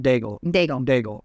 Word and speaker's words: Daigle: the song from Lauren Daigle. Did I say that right? Daigle. – Yeah.